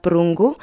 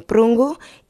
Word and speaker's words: Prungu: If you also maybe want to go on Facebook, you can find me Vivian Nisa Prungu. You can spell it as V-I-V-I-E-N-N-E. And my surname Prungu is P-R-U-N-G-U.